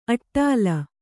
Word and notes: ಅಟ್ಟಲು: aṭṭalu

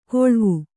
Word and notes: ಕೊಳ್ವು: koḷvu

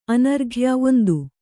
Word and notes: ಅನರ್ಘ್ಯ: anarghya

